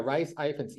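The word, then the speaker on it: Rhys Ifans: Another guy I want to mention is, and I always get his name wrong, is it Rias Ifans? – Yes. – Did I say that right? – Or I think so. That's how I would say it. – Yeah, Rias Ifans.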